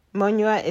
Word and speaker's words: Munyua: Alice is spelled A-L-I-C-E. Monua is spelled M-U-N-Y-U-A.